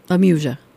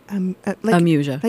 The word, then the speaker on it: amusia: Amusia. Amusia. – Like amusing. It's actually from the Greek, a being negative and muse. So you're without the muse, and technically it means the inability to recognize or reproduce musical sounds, which is why I liked it.